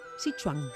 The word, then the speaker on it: Sichuan: Y nos trae Corvina al estilo Sichuan.